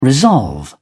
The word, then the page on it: resolve: British English